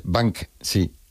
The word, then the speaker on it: Banksy: Y se pronuncia, en España la gente lo dice de otra manera, ¿no? Pero bueno, como Bansky. – Esas palabras. Es Banksy.